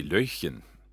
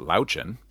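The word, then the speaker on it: Löwchen: It should be Löchen, because it means little lion, basically. And it's a very old breed. He's a cross. But Löchen, or as Kiwis pronounce it, Lauchen, is a very old breed that goes back to, I think, the 16th century, maybe even earlier than that.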